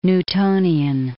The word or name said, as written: Newtonian